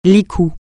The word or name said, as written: licou